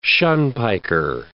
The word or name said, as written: shunpiker